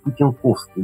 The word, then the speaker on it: Путинковский: А вот здесь, опять же, ударение а, в наши дни принято ставить Путинковский.